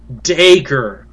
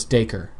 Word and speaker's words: Dacre: Dacre. Like D in front of Acre. Got it. – Yeah, they say it's D-A-Y hyphen K-E-R. So it's Dacre.